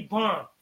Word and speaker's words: bomb: I'm looking in a dictionary, and in parentheses, it was B O M, because the other B is a silent B. You don't hear it. And then right there, I'm gonna be bomb.